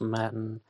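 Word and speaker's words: Mattan: Back in 2019, I did one episode on the death of Mahmoud Hussein Matan, sometimes pronounced Matin.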